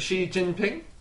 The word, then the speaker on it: Xi Jinping: Is it Xi Jinping? – Uh-huh.